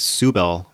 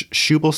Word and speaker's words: Shubal: The book started off on the wrongest of feet, citing Shubal Merritt's name as Subal Merritt, which sounds like someone who identifies as both a woman and a cow, but no, it's Shubal Scudder Merritt is the correct name.